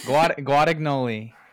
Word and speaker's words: Guadagnoli: How do you say his name? – Guadagnoli. It's always a challenge.